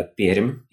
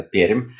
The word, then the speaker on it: Пермь: А сегодня со мной вновь Наталья Емельянова из города Пермь. Я вот правильное произношение с мягкой «ри» выучил. Города Пермь.